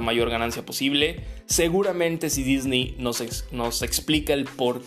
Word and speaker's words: Disney: Tal vez, puede ser, que si se hace mucho ruido, pues Disney, o Disney, como realmente se pronuncia, em, decida cambiar la producción o, o, a la actriz.